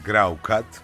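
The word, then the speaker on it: Groucutt: Είναι η περίφημη Electric Light Orchestra, Μια τέτοια μέρα που λέτε, Σεπτέμβρη του 1945, γεννήθηκε αυτός ο Βρετανός μπασίστας που καμιά φορά θέλει προσοχή για το πώς θα προφέρει στο όνομά του, ο Κέλι Γκράουκατ. Αν δείτε και πώς γράφεται, είναι καταπληκτικό, αλλά δεν πειράζει, μην το δυσκολέψουμε.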